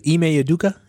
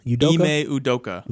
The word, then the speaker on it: Ime Udoka: But say it for me again. – I think it's Ime Udoka. – Ime Udoka.